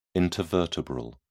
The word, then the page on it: intervertebral: British English